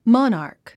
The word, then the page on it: monarch: mάnə r k; マナァク